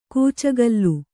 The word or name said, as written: ಕೂಚುಗೋಲು